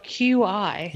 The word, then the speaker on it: Qi: And it's oddly enough pronounced Qi.